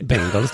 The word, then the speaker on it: Bengals: Du kan ju inte ens uttala Bangals namn. Nej. Bengals då i Vad heter de då?